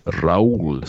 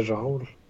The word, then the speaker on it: Raoul: jag minns att Torben sa Torben uttalade hans förnamn Raoul. Mm. – Raoul, som att han vore någon liksom, mexikansk eh, mexikansk trädgårdsarbetare. – ja. ja. ja. Det... Hur ska man säga? Jag tänker Raul.